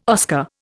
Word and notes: Oscar: Indian

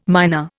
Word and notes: myna: Indian

